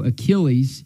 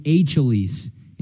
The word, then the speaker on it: Achilles: Instead of saying mythological hero Achilles, he said mythological hero Achilles. And he didn't get credit for the puzzle because he couldn't pronounce Achilles right.